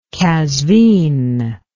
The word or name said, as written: qazvin